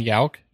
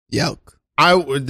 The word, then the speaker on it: Jauch: I'm Brian Jauch. – Brian Jauch. – Jauch.